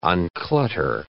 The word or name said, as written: unclutter